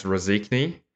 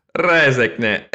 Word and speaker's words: Rēzekne: So, Rēzekne, or as it's locally pronounced, Rēzekne.